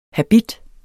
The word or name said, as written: Habitten